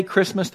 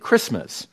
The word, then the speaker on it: Christmas: And if you really want to do that, do not say Christmas the way we normally do. We tend to shorten the I and drop the T and get Christmas. It's actually Christmas. Say, emphasize that. Make the I loud. Pronounce the T.